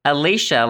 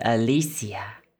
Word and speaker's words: Alicia: It's Alicia, like Alicia Keys or Alicia Silverstone, although French people are more likely to pronounce it Alicia.